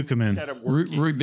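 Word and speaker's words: Ducommun: Why the fuck are these people here when these people are still, they're still, like, okay, Rick DeCommon. I don't know how you pronounce his name. – Dukeman. – There you go.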